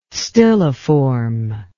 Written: stilliform

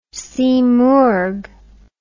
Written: simurgh

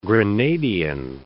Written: Grenadian

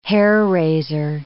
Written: hair-raiser

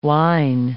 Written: whine

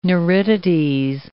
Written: neuritides

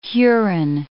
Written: Huron

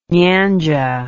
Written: nyanja

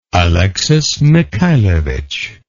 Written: Alexis Mikhailovich

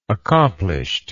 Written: accomplished

